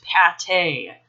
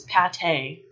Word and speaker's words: pâté: Unfortunately, this subset of humans sounds to me like they don't know any French, because if they did, they would know that the word is pronounced pâté. Moi, j'aime le pâté de canard. It's pâté. Now we're going to get to the Italian words.